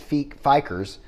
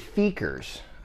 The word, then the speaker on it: Fiekers: We talked about a place that I first mispronounced. I said Fikers, Haunted Hollow. But it's actually Fikers, Haunted Hollow, and I got to visit with one of the individuals of Fikers that helps run it.